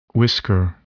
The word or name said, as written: whisker